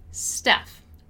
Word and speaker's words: staff: And the pronunciation of staff is staff, right? So staff, staff, staff. Stuff for things in general and staff for personnel. Stuff and staff.